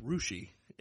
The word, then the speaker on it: Russia: It's spelled Russia. – Mm-hmm. – It's pronounced Rushi in Ohio.